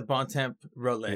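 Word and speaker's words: bon temps rouler: And as they say in New Orleans, we're going to let the bon temp roller. Yeah. Is that how you say it? – Yeah.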